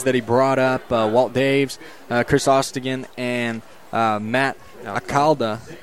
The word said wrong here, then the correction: Alcala: How do you say it? – Alcala. – Yeah, Alcala.